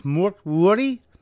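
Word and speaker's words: Mörk Borg: It's the Borg part the Borg? Apparently the G is more of a Y, so it's Morkborg. If I'm pronouncing that correctly, it's more Morkborg.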